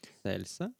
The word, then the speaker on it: Ексцельса: ви можете скуштувати, це буде арабіка, робуста, можливо, десь ви ще зустрінете Ексельсу. Пан, чи я правильно вимовив. Ексцельса? Ексцельса. Ексцельса.